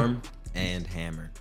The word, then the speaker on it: Arm & Hammer: I said, how you say it? Arm & Hammer? Armor? – Arm and Hammer.